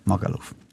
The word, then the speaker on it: Magaluf: Ich habe das so manchmal falsch gesagt. Ich weiß es jetzt nicht. Magaluf? – Ja, Magaluf. – Was haben wir denn oben gesagt? – Malagaf oder in die Magalaf. Magaluf!